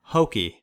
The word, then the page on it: hokey: American English